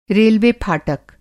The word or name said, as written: रेलवे-फाटक